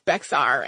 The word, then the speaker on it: Bexar: And if you are a Spanish speaker, you can probably roll your R at the end of that beautifully. If I try to do that, I sound like a child practicing Spanish for the first time. So please pardon my American pronunciation of Bexar.